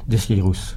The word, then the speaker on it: Desqueyroux: Et par contre, ce qui fait très régional, c'est de dire Desqueyroux. – Desqueyroux. – Mais c'est pas joli. – Oui. Ils ne prononcent pas les X, ils disent... – Ils disent tout.